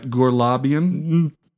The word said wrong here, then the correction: Glorbonian: You have not gotten it right a single time. Glorbonian. – Glorbonian.